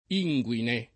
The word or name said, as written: inguine